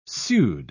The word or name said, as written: pseud